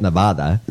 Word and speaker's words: Nevada: But we talked about you're pronouncing it wrong. – Donald. – Say it the Mexican way. – No, Nevada is the way. Nevada is the way a person who speaks Spanish would say it. Nevada.